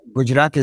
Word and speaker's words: Gujarat: And he developed, when he was governor of the state of Gujarat, if I've pronounced that correctly, or close to it. – Yes, that's Gujarat is very good.